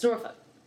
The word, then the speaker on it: Norfolk: It's kind of like Norfolk. You only know people are from Norfolk when they say it right. It's Norfolk. It's Norfolk and from Norfolk. Anybody ever listening to this that is from that area, now you know.